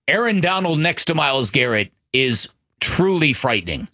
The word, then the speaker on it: Garrett: Myles Garrett, that does rhyme with ferret, just like Jarrett.